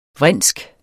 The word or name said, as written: vrinsk